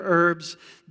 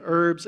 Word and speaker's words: herbs: They shall eat the flesh that night, roasted on the fire with unleavened bread and bitter herbs. They shall eat it. My wife tells me to pronounce that herbs and not herbs. Bitter herbs, they shall eat it. Silent H.